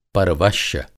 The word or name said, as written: परवश्य